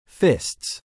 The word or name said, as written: fists